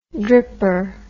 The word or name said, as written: dripper